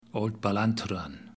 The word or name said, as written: Old Ballantruan